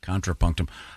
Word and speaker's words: Contrapunctum: No, it's O-O-O. Thank you, Josh. That was C-O-N. – C-O-N. Thank you. T-R-A-P-U-N-C-T-U-M. Contrapunctum.